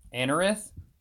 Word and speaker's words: Anorith: I tried to get that stupid Ananith. How do you say it? – Anorith? – That cute, yeah, that Marowak.